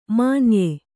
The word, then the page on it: ಮಾನಾಯ: kannada; mānāya